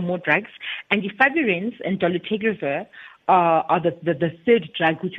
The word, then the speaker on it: dolutegravir: And more recently, the Dolutegravir, how do we pronounce that one? – Dolutegravir has been said to be introduced.